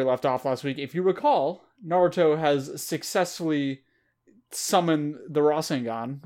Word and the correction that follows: Rasengan: Rasengan? I was putting the emphasis on the wrong syllable.